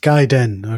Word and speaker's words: Gaiden: It's called Resident Evil Gaiden, right? – Pronounced Gaiden. – Okay. Gaiden, right? – Yeah, there you go. Now, it means side story.